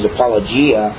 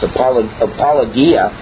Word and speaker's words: apologia: I've heard it pronounced a lot of times apologia. But from what little I know of Greek, I believe it's supposed to be pronounced apologia.